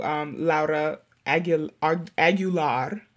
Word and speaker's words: Aguilar: And for those of you who don't know or aren't familiar with who Laura Aguilar, that's how Nikita says it's pronounced, she's basically like a groundbreaking photographer, badass Chicana, did a lot of work for like marginalized bodies in general.